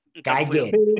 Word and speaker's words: Calle: Y no tengo nada malo que decir de Sasha Cal, de Sasha Cale. – Calle, Sasha Calle.